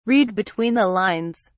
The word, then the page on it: read between the lines: British English